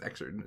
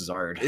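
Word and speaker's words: Xrd: I think it's Exard, isn't it? – It's pronounced Zard. – Is it really? I thought it was Exard for my whole life.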